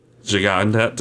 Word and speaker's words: Gigandet: Gigandet? Is that how you say it? – It's French. It's spelled Gigandet, but I think it's pronounced Gigande.